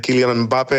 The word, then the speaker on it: Kylian Mbappé: En is dat ze inderdaad Kylian Mbappé hebben. Zo heet hij volgens mij trouwens echt. Dat zegt hij zelf, zo moet je het uitspreken.